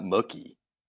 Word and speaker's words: Mookie: It's Mookie, not Mookie. – Mookie. – It's Mookie. – Mookie. – Like a cow.